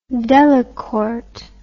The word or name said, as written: Delacorte